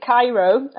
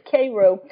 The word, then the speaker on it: Cairo: Sometimes people pronounce that wrong. You know, people pronounce it Cairo, like the Egyptian version. But yeah, it's Cairo, the only spot where people pronounce it that way, Southern Illinois.